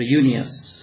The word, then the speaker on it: युनियस: अब एन्ड्रोनिकस र युनियस, यो युनियस भन्ने नाम चाहिँ यहाँ अलिकति अनिश्चयता छ, यो पुरुष हो कि स्त्री हो, किनभने ग्रिकमा चाहिँ यसलाई उच्चारण एक तरिकाले गर्यो भने स्त्री हुन जान्छ, अर्को तरिकाले गर्यो भने पुरुष हुन जान्छ। पुरुष हो भने युनियस यहाँ जस्तो, तर स्त्री हो भने युनिया हुन्छ, हाम्रो कमल र कमला जस्तो।